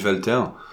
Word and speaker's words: Walter: Bah, je suis assez d'accord, monsieur bah, Walter, parce que rien que, parce que ça s'écrit Walter et ça se dit Walter.